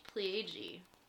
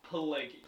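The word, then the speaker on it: Pileggi: Mitch Pelleggi. Am I saying that right? – I think it's Pelleggi. – Pelleggi. Okay.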